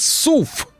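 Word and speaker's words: SUV: Так вот, если вы хотите говорить правильно, надо говорить СУФ. Э, потому что так именно на английском языке э, вот ваш этот кроссовер, который придумали какие-то журналисты, именуют.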